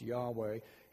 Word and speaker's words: Yahweh: We think that's the correct pronunciation. I say we think because it's just consonants. There's no vowels. But later scribes of the Hebrew Scriptures plugged in vowels, and the best we can determine it's probably pronounced Yahweh.